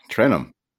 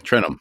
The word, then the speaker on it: Trentham: I say Trentham. So, I almost say it like Garrett, a TH is silent. – Oh, Trentham. Okay, Trentham.